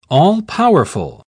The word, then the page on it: all-powerful: British English